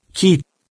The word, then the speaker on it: chi: Il simbolo generalmente usato per indicare la frazione molare è la lettera greca chi, che si legge chi.